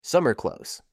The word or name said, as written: summer clothes